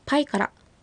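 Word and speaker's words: パイカラ: はい、パイからという発音、最後に小さいラが来ますので軽く発音してみてください。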